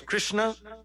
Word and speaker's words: Krishna: I'm telling you the correct pronunciation. He is saying Krishna, and that is not the correct pronunciation. It is Krishna.